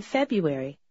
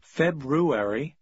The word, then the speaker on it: February: In February. – Также вы можете услышать, что иногда произносят February. – Обе формы правильные.